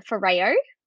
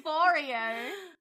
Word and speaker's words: Foreo: I've been saying Foreo. Like an Oreo.